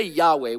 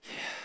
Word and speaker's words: Yahweh: If you were to try to pronounce this name, we say Yahweh, we insert an A and an E to make it easier. But if you were actually to try to pronounce this name in its authenticity, it would be pronounced like this. Yahweh. Very breathy.